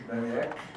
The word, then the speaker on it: Mamayek: My name is Richard Maniac, and I live at 104 Tamsin Way. – Could you spell your last name, please? – M-A-M-A-Y-E-K. – Thank you. – Say it like it's spelled.